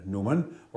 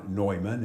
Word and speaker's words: Neumann: And we also have here a first-class relic of St. John Newman, or St. John Neumann, as you sometimes hear his name pronounced.